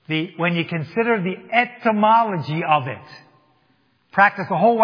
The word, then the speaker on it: etymology: Do not confuse that with entomology. Entomology is the study of bugs. Etymology is the study of words.